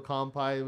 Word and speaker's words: kanpai: Actually, in Japanese, it's K-A-N-P-A-I, but, you know, I spell it with an M just because people I guess they pronounce it more with the M, but anyways, Dan, this is to you.